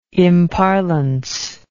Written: imparlance

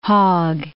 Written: Hogg

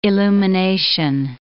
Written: illumination

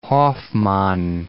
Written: Hofmann

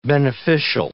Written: beneficial

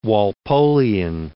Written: Walpolian

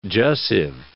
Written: jussive

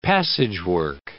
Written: passagework

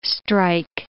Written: strike

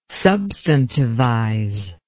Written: substantivize

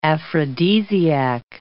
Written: aphrodisiac

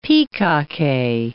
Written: pikake

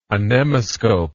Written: anemoscope

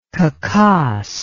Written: Khakass

